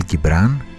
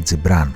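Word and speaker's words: Τζιμπράν (جبران): Ο Χαλίλ Γκιμπράν ή Χαλίλ Τζιμπράν όπως είναι η ορθή προφορά στα αράβικα είναι γνωστός στο παγκόσμιο κοινό ως ο άνθρωπος από το Λίβανο και υπήρξε ποιητής, φιλόσοφος και ζωγράφος.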